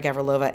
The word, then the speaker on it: Gavrilova: she prefers the Australian pronunciation of her name, Daria Gavrilova and Sam Stozer making the final in Strasbourg.